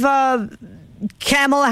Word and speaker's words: Kamala: And then you've got Michelle Obama up there going, well, people are, and actually, I believe Kamala Harris, same thing.